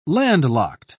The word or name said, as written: landlocked